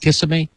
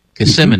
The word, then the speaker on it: Kissimmee: We got Emily from Kissimmee, Florida. I think they call it Kissimmee. Kissimmee. Mm-hmm. There you go. Kissimmee. Mm-hmm. That's how you say it.